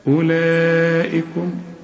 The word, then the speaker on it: أولئكم: ما انت فاتح المصحف وماشي معايا، الواو عليها خمسة، خمسة مدورة كده، اسم حرف علة يكتب ولا ينطق، تلات حروف إذا لقيت عليهم الخمسة المدورة دي الدوران ده الغيهم من النطق. ايه هم؟ الألف والواو والياء، تلاقيهم زي دي. أولا، ما اوعى تقول لي أولئك، لا، أولئكم. وعندك مد أربع حركات، احنا قلنا عليه مد واجب زي ما قلنا ايه جاء، هنا برضو أولئكم، والهمزة هنا مكسورة، أولئكم.